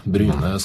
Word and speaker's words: Bryne: På Bryne? – Bryne, du tar det litt fort. Bryne skal uttales litt sakte.